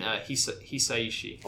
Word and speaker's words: Hisaishi: Princess Mononoke is great, and I think the movie I've watched many times, but Joe Hitachi's soundtrack, I've probably listened to even more. – It's very good. Oh, yeah. – Love the music. – You mean Hisaishi.